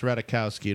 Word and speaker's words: Ratajkowski: The only thing that I can say, and I'm going to do my best here, Emily Ratajkowski, this girl is like a little wave, but that face and those sexy eyes. – It's just pronounced Ratajkowski. Don't pronounce the J. – It's like Ratatouille, but different.